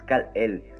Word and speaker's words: Kal-El: Ok, yo cuando dijiste Kalek dije Kanek, no, es Kal-El.